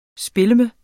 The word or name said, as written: spilleme